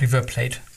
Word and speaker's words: River Plate: 2005 River, äh, spricht man es eigentlich River Plate aus oder River Plate? Ich bin mir nicht sicher. – River Plate. – River Plate? – Ja.